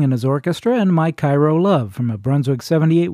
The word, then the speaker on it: Cairo: Last week we had a segment of the show on Illinois, which included a song about Cairo, Illinois. It's pronounced Cairo, even though it's spelled the same as Cairo, the city in Egypt, which is where we're headed now in this segment, courtesy of Art Hickman's orchestra.